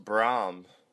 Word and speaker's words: Bram: I think it's pronounced Brahm.